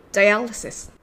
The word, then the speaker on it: dialysis: dialysis と発音します。では練習しましょう。これぐらいで済んでラッキーでしたね。今週透析飛ばしたんですか ?You're lucky. Skip dialysis this week.